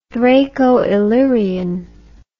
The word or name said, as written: Thraco-Illyrian